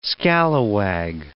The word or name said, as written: scalawag